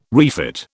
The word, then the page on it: refit: British English